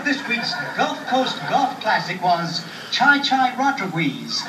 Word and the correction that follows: Chi Chi Rodriguez: Chai Chai finished with 9 under par score. – Chi Chi Rodriguez.